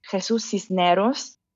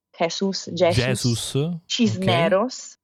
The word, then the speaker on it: Jesús Cisneros: E con me c'è Jesús Cisneros, che si pronuncia così, ma è scritto... Jesús Cisneros, lo dico così perché così gli italiani lo googolano perché è bravissimo, è uno dei miei illustratori preferiti.